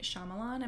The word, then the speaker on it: Shyamalan: Night Shyamalan. Am I saying his name right? – Yeah.